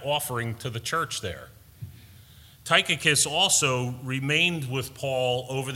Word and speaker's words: Tychicus: Tychicus, that's how you say it, Brian. Sorry about that. That was a rough one. I said it wrong all week. in my own mind, but Tychicus is how you say that name.